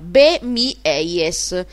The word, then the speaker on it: Be My Eyes: Allora, Be My Eyes si scrive Be, Bologna Empoli, spazio Mi, con la M-Y, Milano York, spazio EIS con la Y, sarebbe Empoli York, Empoli Savona, Be Mi Eyes, che vuol dire in inglese sì, i miei occhi.